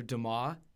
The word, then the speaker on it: Dumas: Written by Alexander Dumas. That's how you pronounce that last name, just so we're all clear.